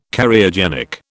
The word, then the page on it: Cariogenic: British English